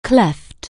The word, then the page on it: cleavage: anglais